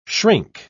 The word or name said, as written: shrink